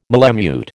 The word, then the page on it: malemute: American English